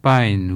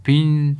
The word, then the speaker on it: Pyne: et donc qui est proposé par Martin Pine, ou Pine, ça dépend comment on prononce, c'est P-Y-N-E.